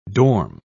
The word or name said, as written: dorm